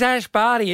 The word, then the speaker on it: Barty: She's Ash Barty. It rhymes with party.